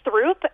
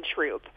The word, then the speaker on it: Throop: I remember them laughing at me for saying Paulina and Throop instead of Paulina and Troop. So I guess I've always wondered why we pronounce those that way.